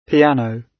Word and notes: piano: British English

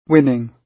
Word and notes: winning: British English

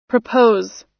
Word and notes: propose: American English

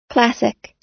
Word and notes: classic: American English

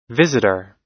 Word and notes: visitor: American English

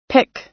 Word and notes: pick: American English

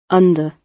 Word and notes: under: British English

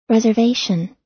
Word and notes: reservation: American English

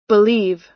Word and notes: believe: American English

